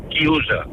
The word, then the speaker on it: chiusa: Y un momento, y quiero decirle a Fernando que en italiano se dice chiusa.